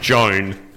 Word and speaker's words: Joan: You know, thanks, Joanne. – Last lap, Joan, mate. It's pronounced Joan.